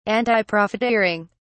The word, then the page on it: antiprofiteering: British English